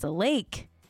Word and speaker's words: Laich: Okay, it's Lake. L-A-I-C-H. But it's pronounced like a body of water. A lake.